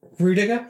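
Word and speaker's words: Rüdiger: Rudiger, is that how you pronounce it? – Yes, yeah.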